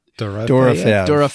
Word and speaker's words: Dorofeev: Pavel Dorofeev.